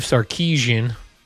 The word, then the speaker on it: Sarkisian: Arch Manning got in trouble for somebody showing him an AI video of Steve Sarkisian.